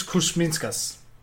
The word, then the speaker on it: Kuzminskas: We need to learn how to pronounce his name by next year. That's the real trick. – Definitely. – Daukis Kuzminskas. – Oh, there you, all right. We have a European on the podcast. That's where it will be our official pronunciation guide for him.